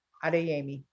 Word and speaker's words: Adeyemi: Adeyemi.